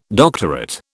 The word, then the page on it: doctorate: British English